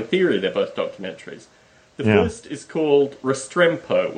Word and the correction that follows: Restrepo: which is spelled Restrepo.